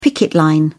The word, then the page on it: picket line: British English